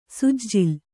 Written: ಸುಜ್ಜುಲು

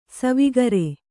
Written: ಸಾವುಕಾರಿ

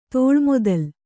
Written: ತೋಳ್ಮೊದಲು

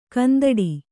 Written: ಕಂದೊಡಿ

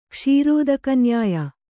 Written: ಕ್ಷೀರೋದಕನ್ಯಾಯ

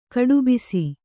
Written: ಕಟ್ಟುಬ್ಬಸ

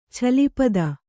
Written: ಛಲಿಪದ

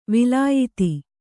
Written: ವಲಯಿತ

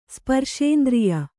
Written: ಸ್ಪರ್ಶೇಂದ್ರಿಯ

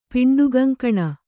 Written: ಪಿಂಡುಗಂಕಣ

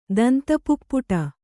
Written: ದಂತಪುಪ್ಪುಟ

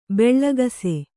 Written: ಭಾಳಾಕ್ಷ

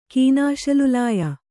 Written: ಕೀನಾಶಲುಲಾಯ

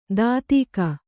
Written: ದಾಧಿಕ

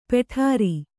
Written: ಪುಡಾರಿ

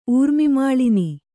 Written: ಊರ್ಮಿಮಾಳಿನಿ

